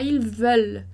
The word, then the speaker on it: veulent: E como para os verbos regulares, o ENT a gente não vai pronunciar. Então, não pronuncia o ENT do final da conjugação ali. Então, vai ficar ils veulent. Vai terminar no L, né?